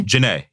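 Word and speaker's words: Jhené: But it is Janae? – Janae, yeah.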